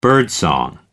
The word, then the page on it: birdsong: American English